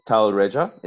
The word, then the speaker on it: Talreja: Uh, so today's guest is Annu Talreja.